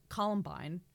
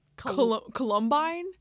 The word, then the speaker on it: Columbine: During our practice or pre-conversation about what we want to talk about in this episode, had continued to pronounce Columbine as almost like Columbine. – Columbine. – Yeah. – Columbine.